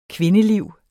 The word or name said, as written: kvindeliv